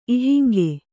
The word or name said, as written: ಇಹಂಗೆ